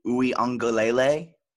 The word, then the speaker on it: Uiagalelei: So my next question will be, with Trevor Lawrence out and DJ Uiagalelei, is that how you pronounce his name? – Yeah.